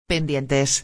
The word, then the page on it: pendientes: espanhol